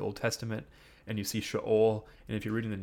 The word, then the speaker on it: Sheol: So you might say Sheol or Sheol, but I'm pretty sure the Hebrew pronunciation is Sheol, if I remember correctly, Lucas, right, from our Hebrew? – I think so.